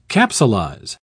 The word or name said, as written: capsulize